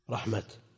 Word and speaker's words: رحمة: تكتب بالتاء رحمة، لكن هل يمكن أن يوقف عليها رحمة؟ إن رحمة الله قريب، تكتب بصورة التاء وتنطق هاء. وهنا هل يمكن أن يقال لا حين وإن كتبت بالتاء مثل رحمة؟ لا يمكن. ولو كانت في الأصل هاء ثم كتبت تاء لاستمر النطق بها هاء مثل رحمة.